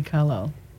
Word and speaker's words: Carlisle: Is there any Carlisle? – I don't know how to say the name. – You got it right. Belinda Carlisle.